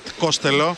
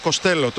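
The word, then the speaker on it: Costello: Πώς προφέρει το όνομά σου, λέει Ματ Κοστέλο. Τότε λέει γιατί σε φωνάζουμε έτσι, γιατί λέει κάποτε σε έναν δημοσιογράφο είχα πει Κόστελο γιατί του φάνηκε πιο εύκολο.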